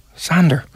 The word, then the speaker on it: Xander: And his son, how do you say Xander? X-A-N-D-E-R? – Sure. We'll go with Xander. It's not Alexander, but is that Xander? – Xander is a name on its own, yeah.